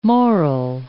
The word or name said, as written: moral